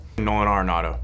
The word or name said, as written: Nolan Arenado